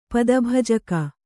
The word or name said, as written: ಪದಭಜಕ